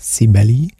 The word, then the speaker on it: Cibele: Sie nennt sich Cibele, wird geschrieben wie die französische Cibele, aber das E am Ende betont man nochmal, Cibele. Könnte sagen,